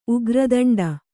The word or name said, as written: ಉಗ್ರದಂಡ